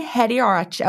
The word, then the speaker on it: Hettiarachchi: Today, I'm here with Navin Hettiarachchi. Oh, I said it wrong. Navin, say your last name for us. – It's Hettiarachchi. – Thank you. Hettiarachchi. I practiced before we started.